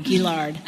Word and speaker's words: Gillard: Like the fact, there is some hope for me in the fact that that that Julia can, Gillard, Gillard, Gillard, Gillard. Yeah. I always say that wrong, Gillard.